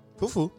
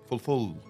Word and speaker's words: fufu: Fufu. – What? – Fufu. – Fufu. – If you. – Fufu, bro. You can't you say it properly? – Fulful. – Fulful. – It's fufu, like...